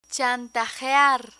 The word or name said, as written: chantajear